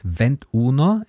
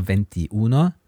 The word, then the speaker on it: ventuno: So there are three places where there's a small adjustment, which is vent uno instead of venti uno.